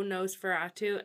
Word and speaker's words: NOS4A2: It's made into the show, Nosferatu, and it's spelled N-O-S-4-A-2.